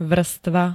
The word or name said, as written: vrstva